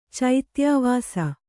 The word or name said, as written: ಚೈತ್ಯಾವಾಸ